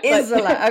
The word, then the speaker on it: Isola: I was corrected myself yesterday by a friend who knows Italian. She goes, Isola. – Isola, okay.